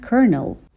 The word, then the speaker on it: colonel: As a lieutenant colonel. Lieutenant Colonel به معنای سرهنگ دوم هستش. به تلفظ کرنل دقت بکنین که اصلا ربطی به اون چیزی که می نویسیم نداره کلن.